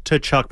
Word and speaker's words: Tkachuk: No, we don't pronounce the T. Silent T. – What Tkachuk. – It's just Tkachuk? – Big hockey guys here.